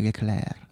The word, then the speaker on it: Leclerc: Perché, perché il, il, suono duro finale non si pronuncia di No, è Leclerc.